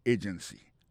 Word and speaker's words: agency: Pour mot agency, hein, accent tombé sous premier syllabe là. Agency. Deux autres syllabes qui sont derrière ou faible.